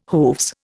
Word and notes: hooves: Indian

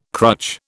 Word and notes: crutch: British English

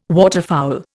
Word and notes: waterfowl: Indian

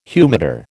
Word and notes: humidor: American English

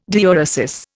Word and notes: diuresis: Indian

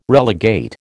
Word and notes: relegate: American English

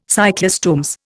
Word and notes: cyclostomes: Indian

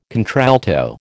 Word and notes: contralto: American English

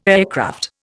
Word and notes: aircraft: Indian

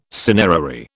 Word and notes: cinerary: British English

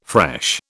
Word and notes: fresh: British English

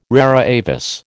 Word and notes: rara avis: American English

